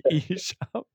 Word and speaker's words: Esham: Is it Esham? – Isham. Just the length of the E.